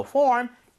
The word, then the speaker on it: form: So form, 양식. 발음할 때 여러분 제가 항상 트레이닝 시켜드리죠. 그냥 form. form은 더안 됩니다. F니까 입술 붙으면 안 돼요.